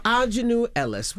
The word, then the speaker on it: Aunjanue Ellis: We have Aunjanue Ellis.